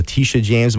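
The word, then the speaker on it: Tish James: I don't think I have enough time right now because I think in the next, you know, few weeks with the Tisha James, am I saying it correctly? – You are.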